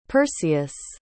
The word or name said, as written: perseus